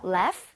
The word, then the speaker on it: laugh: Laugh. – Laugh? Okay. 자, 그러면 여기 써주세요. – Laugh at... – 스펠링을 제대로 만들어주세요. – Laugh... 같은데요? – GH는 F와 비슷하게 발음이 됩니다.